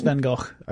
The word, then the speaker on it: van Gogh: It's van Gogh. – I've said it wrong my entire life. – Yeah. Yeah. And with a kind of FV at the beginning of van Gogh.